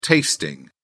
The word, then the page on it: tasting: British English